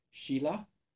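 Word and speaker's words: śīla: And in Sanskrit, it just has a stroke over the S, which is a sh. So, shila or sila.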